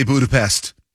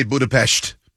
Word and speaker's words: Budapest: Yes, here in America, we say Budapest. If we really want to say it correctly, we'd say Budapest.